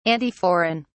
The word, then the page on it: antiforeign: British English